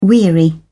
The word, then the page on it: weary: British English